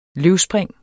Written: løvspring

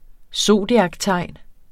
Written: zodiaktegn

